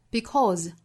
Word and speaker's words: because: 单词尾部的 se 读成浊音的 z， because 或 because tomorrow the sun will rise，还是会升起的。